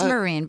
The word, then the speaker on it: Myrin: When you have a name that's spelled like that and pronounced so differently, you can't get your panties in a wad if people say it wrong. I don't care. It's Myrin, but whatever.